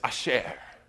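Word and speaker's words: asher (אשר): I listened to its pronunciation like 12 times last night, and my wife turned to me and thought I had gone crazy because I was just sitting there listening to a woman say asher over and over and over again. But by God, that's how you pronounce it, according to some random user from Israel, right? But she says, it's Asher, Asher.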